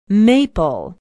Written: maple

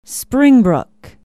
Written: springbrook

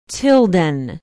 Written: tilden

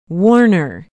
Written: warner

